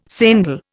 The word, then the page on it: sendal: Indian